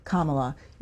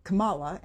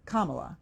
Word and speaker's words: Kamala: You said Kamala, and it's Kamala. – Kamala, okay.